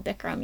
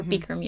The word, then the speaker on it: Bikram: I started with a really traditional Bikram yoga, or Bikram yoga, I guess is how you're supposed to pronounce it.